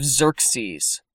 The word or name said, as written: Xerxes